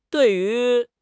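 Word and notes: dui4 yu2: tones 4, 2

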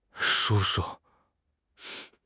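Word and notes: shu1 shu5: tone 1; tone 5

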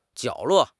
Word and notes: 角落: HSK6

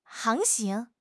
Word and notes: 航行: HSK6

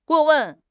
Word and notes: 过问: HSK6

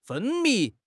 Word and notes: 分泌: HSK6

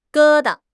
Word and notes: 疙瘩: HSK6

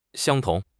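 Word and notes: xiang1 tong2: tones 1, 2

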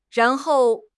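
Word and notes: ran2 hou4: tone 2; tone 4

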